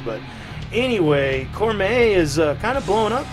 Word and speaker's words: Cormae: And anyway, she took off for Austin, Texas, and started a really good band called Cormae, I think it's pronounced. – Mm-hmm. – C-O-R-M-A-E, Cormae.